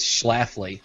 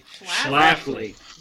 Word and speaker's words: Schlafly: The first question on their FAQ is how do you pronounce their name? It's Schlafly. – Schlafly.